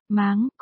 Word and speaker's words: Mang: Một, Mang trong tên A Mang của nhân vật là Mang, phát âm là, máng, có nghĩa là bận rộn.